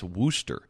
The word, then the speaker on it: Worcester: He says, "Victuals is one of those British words that is not pronounced the way it is spelled, like Worcester is pronounced Wooster.